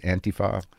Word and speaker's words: Antifa: Was that I started to see this footage of these protests that would happen between, you know, alt-right or white nationalists and Antifa. Is that how you say it? Antifa? – Yeah, that's how I say it.